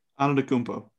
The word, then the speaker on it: Antetokounmpo: Antetokounmpo.